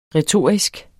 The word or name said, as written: retorisk